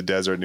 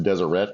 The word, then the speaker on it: Deseret: Because like this podcast is how I learned that it's pronounced Deseret, because I always assumed it was Deseret.